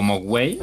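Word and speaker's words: mogwai: o moguay en la pronunciación gringa, como lo pronuncian ellos que literalmente eh, en chino mandarín significa espíritu maligno.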